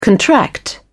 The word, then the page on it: contract out: British English